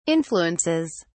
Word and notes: influences: British English

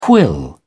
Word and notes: quill: British English

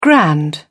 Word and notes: grand: British English

